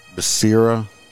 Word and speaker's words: Becerra: Becerra, B-E-C-E-R-R-A, that's how you say it.